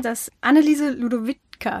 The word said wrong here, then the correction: Ludovici: Ludovici. – Ludovici. Ludovici.